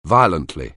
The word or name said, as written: violently